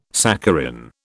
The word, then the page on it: saccharin: British English